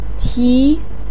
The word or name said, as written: ti2